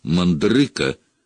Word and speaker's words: Мандрыка: Его фамилия Мандрыка, — поправил Здор негромко, но твердо, с ударением на букве «ы».